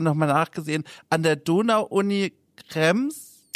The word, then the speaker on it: Krems: Nein, du hast es ganz richtig ausgesprochen, Krems.